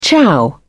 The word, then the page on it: ciao: British English